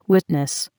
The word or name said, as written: witness